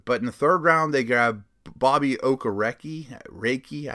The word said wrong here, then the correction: Okereke: I always say his name wrong, and it's not it's not a hard one to say, but I always say it wrong. Okereke one real note on him length.